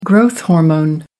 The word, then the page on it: growth hormone: American English